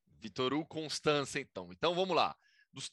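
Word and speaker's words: Constanța: Me corrija a pronúncia, por favor, porque eu tenho certeza que eu não falei corretamente o nome do clube. Mas é, como é que é? – É Vitoru Constança.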